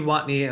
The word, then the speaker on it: Watney: Alana Rizzo was the on-field reporter, and the pregame show was hosted by Heidi, how do you say her last name, Watney? – Heidi Watney and Lauren Gardner. Watney and Lauren Gardner.